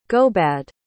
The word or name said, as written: gobad